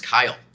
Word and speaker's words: Keil: And I hate to correct you in my first sentence, but as many people do, my name is actually pronounced Kyle. I know we're on a first name basis, so... I won't hold that against you, but it's unlike it's spelled. – Seriously, it is pronounced Kyle.